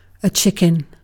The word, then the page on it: chicken: British English